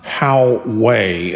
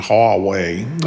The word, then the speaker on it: Huawei: It's not Huawei, it's not Huawei, it's kind of in between, Huawei. I don't even say it right, but I try.